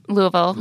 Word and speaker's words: Louisville: Is it pronounced Louisville? Yeah.